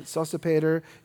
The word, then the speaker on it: Sosipater: That's how you pronounce it. Sosipater is like Darth Vader.